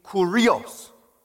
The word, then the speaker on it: kurios: It means Kurios. That's how you pronounce it in the Greek.